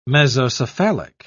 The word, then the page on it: mesocephalic: British English